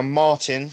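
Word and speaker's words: Martyn: So, I'm now Martin, spelt with a Y because I like to be awkward, .Harvey.Photography on Instagram and Facebook.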